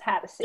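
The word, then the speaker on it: Hatosy: Sean Hatosi. – I think it's Hattacy. – Yeah. Okay. Hattacy. Either way. – It's spelled Hattosy.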